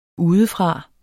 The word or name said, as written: udefra